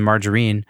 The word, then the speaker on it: margarine: You sent me a track called Cigarettes and Margarine. I'm pronouncing it the British way.